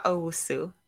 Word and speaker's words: Owusu: Safua Owusu.